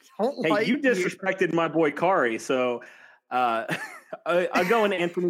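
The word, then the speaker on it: Khari: Whenever we talked about Kari Willis, they wanted to tell you, that the H is silent. So it's not Kahari, it's Kari.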